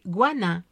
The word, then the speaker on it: Guanin: L'or, hein, qui fait courir le monde entier, l'or, c'est Guanin. Guanin, disons guanin, ouais, guanin, parce que la prononciation est hachée. On dit guanin, voilà, là, dans le Yucatan.